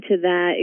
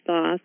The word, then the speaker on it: Thoth: And my experience of Thoth, and it's interesting because I learned his name is Thoth, the pronunciation, and then there can be many different pronunciations. In Egypt, they say Tot, Jehudi, Jehudi. And when I started divinely transmitting him, it came through as Toth.